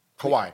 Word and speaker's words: Kawhi: So if you're not, can you say his name right by now? – Kawhi. – Kawhi. – Yeah. Kawhi. Yeah.